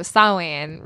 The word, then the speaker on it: Samhain: I mean, it's the whole point, I think, of, you know, Samhain, really. – Nice pronunciation. – Oh, thank you.